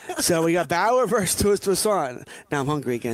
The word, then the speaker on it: Toussaint: Toussaint. – He sounds like a French, he does. – A French baguette, kind of, right? Toussaint. – I was thinking croissant, but okay.